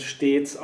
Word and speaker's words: stets: Und da hat man dann eben die Version, dass man nach dem S-T-E-T wieder ein Vokal hat und dann heißt es stetig und stets ist die verkürzte Version daher, würde ich sagen, wird stets auch stets ausgesprochen.